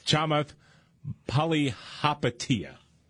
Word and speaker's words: Chamath Palihapitiya: Chamath Palihapitiya.